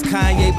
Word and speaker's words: Kanye: Now all I need is y'all to pronounce my name. It's Kanye, but